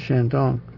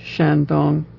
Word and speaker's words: Shandong: There's Shandong. It's two even-pitched syllables. Shandong, Shandong, Shandong, Shandong.